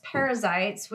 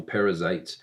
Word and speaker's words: Perizzites: So just so you didn't think that Elizabeth just mispronounced parasites and called them parasites, it's P-E-R-I-Z-Z-I-T-E-S.